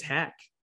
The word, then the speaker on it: Heck: And she wondered why I hadn't parlayed the fact that my legal last name is Heck, H-E-C-K, just like it's spelt.